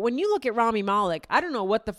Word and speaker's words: Malek: Rami Malek.